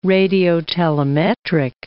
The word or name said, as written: radiotelemetric